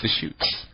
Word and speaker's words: Deschutes: Deschutes? – Deschutes.